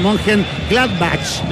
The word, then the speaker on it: Mönchengladbach: A ver si lo digo bien. Mönchengladbach, lo dije bien, ¿no es cierto? – Héctor, ahí está.